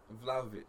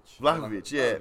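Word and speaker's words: Vlahović: Vlahovic. – Vlahovic.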